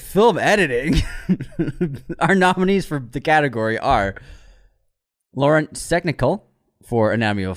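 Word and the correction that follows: Laurent: I butchered that oh, LaRon. Sorry, LaRon. it's got the T at the end.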